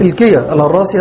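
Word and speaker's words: إلكيا الهراسي: وابن برهان هذا لازم الكية الهراسي، الكية الهراسي، الكية تنطق هكذا بتسكين اللام وكسر الكاف، الكية الهراسي، هذا يعد من كبار علماء الشافعية، واسمه علي بن محمد.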